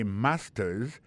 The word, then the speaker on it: master's: Regardez bien pour voir que l'accent tombé sur le premier syllabe là. I got a master's.